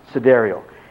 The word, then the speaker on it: sidereal: So we could, this is pronounced sidereal. It's spelled sidereal, but it's pronounced sidereal.